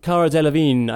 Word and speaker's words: Cara Delevingne: But something I want to point out with, you guys seem to be quite harsh on Cara Delevingne. Is that how you say her name? – Cara Delevingne, I think, yeah.